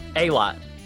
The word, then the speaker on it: Aylott: Is it Aylott? – Aylott. It's Aylott. Like a whole big bunch.